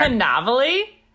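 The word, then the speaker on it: Cannavale: So I know how to pronounce his last name. It's Canav. I have no idea how to say it, but there's no R. – Canavale? – Canavale.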